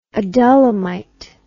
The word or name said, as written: adullamite